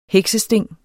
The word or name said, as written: heksesting